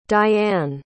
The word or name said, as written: Dyann